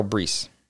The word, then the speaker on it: Bruess: And it's by someone named Carol Bruce. – Carol Brees. – Brees. How did you know her? – It rhymes with peace in her bio.